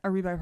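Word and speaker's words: Areebi: We have Jasmine Aribi. I'm probably saying it wrong. It's A-R-E-E-B-I. Aribi. Aribi? – Aribi probably sounds way better.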